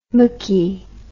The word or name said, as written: machy